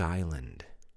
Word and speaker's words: island: I-S-L-A-N-D. Repeat, please. I-S-L-A-N-D. I-S-L-A-N-D. Island. Remember, the S is silent.